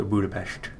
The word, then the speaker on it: Budapest: Well, S's in Hungarian are pronounced sh, so Budapest.